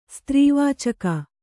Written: ಸ್ತ್ರೀವಾಚಕ

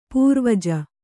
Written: ಪೂರ್ವಜ

